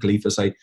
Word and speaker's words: glyphosate: uh, I've forgotten how to pronounce it, uh, glyphosate. – I believe that's how you pronounce it. Yeah. Yeah, it's glifosato in Spanish, but glyphosate, glyphosate.